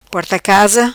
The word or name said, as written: Porta casa